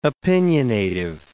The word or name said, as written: opinionative